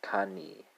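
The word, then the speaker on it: kanë: And that's uh, spelled uh, Kane, right? Uh, K A uh, N E, right? So kind of similar to cane sugar,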